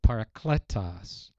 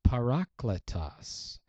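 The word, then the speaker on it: parakletos (παράκλητος): I've always pronounced it, the Greek word for counselor, comforter, I've always pronounced it parakletos. But in Greek, it's parakletos. The emphasis is on the middle syllable.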